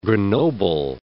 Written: Grenoble

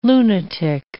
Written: lunatic

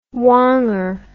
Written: wanger

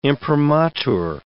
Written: imprimatur

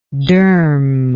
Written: derm

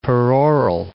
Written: peroral

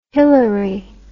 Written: hillery

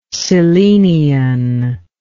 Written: cyllenian

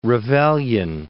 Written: Ravelian

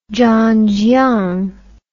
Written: zhanjiang

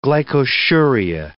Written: glycosuria

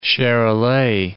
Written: Charolais

